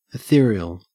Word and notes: ethereal: Australian English

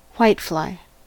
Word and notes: whitefly: American English

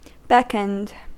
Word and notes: beckoned: American English

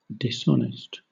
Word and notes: dishonest: British English

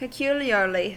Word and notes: peculiarly: American English

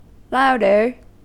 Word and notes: louder: American English

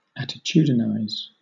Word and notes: attitudinize: British English

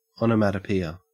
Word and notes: onomatopoeia: Australian English

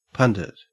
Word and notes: pundit: Australian English

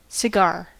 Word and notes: cigar: American English

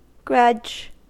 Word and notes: grudge: American English

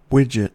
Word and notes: widget: American English

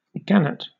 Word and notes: gannet: British English

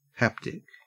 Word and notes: haptic: Australian English

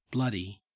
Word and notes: bloody: Australian English

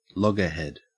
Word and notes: loggerhead: Australian English

